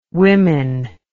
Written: wimmin

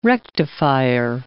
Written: rectifier

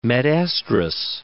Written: metestrus

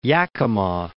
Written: Yakima

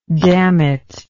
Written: dammit

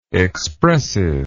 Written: expressiveness

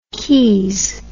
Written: keys

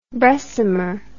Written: bressummer

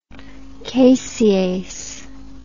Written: casease

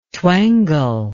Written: twangle

